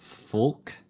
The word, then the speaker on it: folk: Folk? Yeah, folk. – Folk. – I stand by folk. – Instead of folk. – Folk. – Folk. – They say don't pronounce the L.